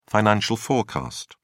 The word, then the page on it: financial forecast: British English